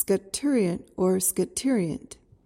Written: scaturient

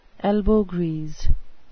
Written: elbow grease

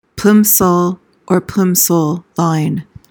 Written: plimsoll line

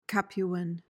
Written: capuan

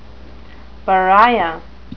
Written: pariah

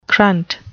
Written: crunt

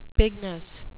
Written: pignus

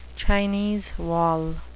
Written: Chinese wall

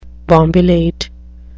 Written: bombilate

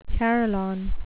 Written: carillon

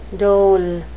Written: dol

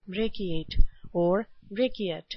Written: brachiate

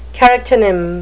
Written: charactonym

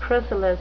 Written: chrysalis

